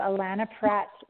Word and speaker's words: Allana Pratt: So Allana Pratt is spelled A L L a N A P R A T T dot com.